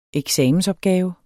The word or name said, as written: eksamensopgave